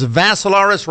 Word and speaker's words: Vassilaris: My profile page is Dimitri with three I's, D-I-M-I-T-R-I. Last name is Vassilaris, rhymes with Assilaris, but you add a V to it.